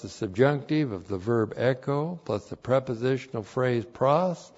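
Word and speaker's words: echo (ἔχω): Hina plus the subjunctive introduces a purpose clause, and you translate it, for the purpose that, and then the verb echo, E-C-H, long O, echo.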